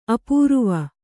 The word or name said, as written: ಅಪೂರುವ